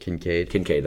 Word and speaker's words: Kincaid: Kincaid, that's what I thought.